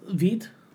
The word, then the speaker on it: Veidt: V-E-I-D-T. let's decide on Viet. – It's Viet. – Let's Viet. Decide on Viet. I'll have to watch the movie again, but... – say Viet.